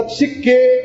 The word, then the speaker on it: psique: Moléstia psicossomática significa, portanto, a doença do corpo gerada por influência do psiquismo, da psique na pronúncia fechada.